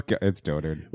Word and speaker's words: dotard: It rhymes with goatherd. – It says right. Rhymes with? – Yeah. – That's for Tom. – Yeah. – Tom's rhyming and stealing. – All right, so it's dotard.